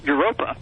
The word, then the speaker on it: Europa: Now, a lot of your listeners may not know this, but Europe comes from, it's a mispronunciation, it really should be pronounced Europa. – Europa.